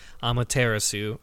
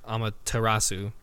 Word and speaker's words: Amaterasu: You play as a wolf, which you pointed out, a wolf goddess, the goddess of the sun, Amaterasu, or Amaterasu, I think is how you pronounce it.